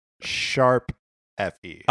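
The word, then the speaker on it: ♯FE: So I think it's pronounced sharp edge. I believe it is pronounced Tokyo Mirage Sessions sharp FE.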